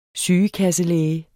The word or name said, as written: sygekasselæge